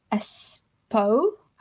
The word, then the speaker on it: Espoo: So we'll jump to Grand Prix of Espoo, I think it's pronounced.